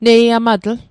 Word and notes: neiamatl: Mi'gmaq

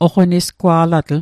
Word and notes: oqonisgwa'latl: Mi'gmaq

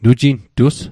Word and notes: nujintus: Mi'gmaq

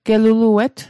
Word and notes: geluluet: Mi'gmaq

